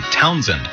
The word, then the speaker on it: Townsend: T-O-W-N-S-E-N-D, Townsend. It rhymes with godsend.